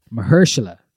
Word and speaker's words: Mahershala: It stars Mahershala. That's how you say it.